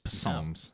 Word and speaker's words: Psalms: No, it's Psalms. No, I'm just reading. – Psalms. – That's all I'm doing. Psalms. The guy that wrote most of the Psalms. – We should put silent P's in front of our names.